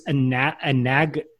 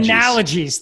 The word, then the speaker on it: analogies: Anagoles? Is that right? – Analogies.